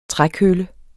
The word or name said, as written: trækølle